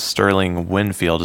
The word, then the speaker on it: Sterling Winfield: Today we have a cool guest, Sterling Winfield.